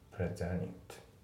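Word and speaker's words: presented: で発音なんだけど、プレゼンティドの T がねほぼ抜けてるような感じですね。プレゼニットプレゼンティドって言ってですね、プレゼニットですね。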